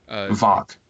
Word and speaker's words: Voq: I mean, we met her before in the first two episodes, but basically now she's Tox. Am I saying that right? Tox, isn't that his name? – Vok. – Vok, I'm sorry. – V-O-Q. – V-O-Q, yeah.